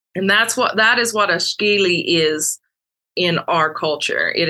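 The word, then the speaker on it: Skilly: Skeely, S-G-I-L-I, is how it's spelled phonetically in Cherokee. Up here, we put a little mountain slang to it. So it's S-K-I-L-L-Y, Skeely.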